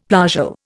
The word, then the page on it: plagal: Indian